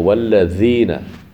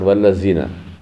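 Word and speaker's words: wallazina (الذين): So wallazina, not wallazina. We all pronounce wallazina, which is wrong. Wallazina, za. The tongue comes here between the teeth, wallazina.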